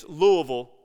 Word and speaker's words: Louisville: It's Louisville, Kentucky, if anybody of you don't know how to pronounce that word. It's Louisville, Kentucky.